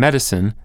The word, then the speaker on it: medicine: And there's the matter of different consonant sounds in a pair of related words, two C sounds in medicine and medicate.